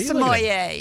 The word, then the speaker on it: sommelier: Oh, yeah, look, he looks just like a sommelier.